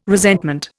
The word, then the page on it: resentment: Indian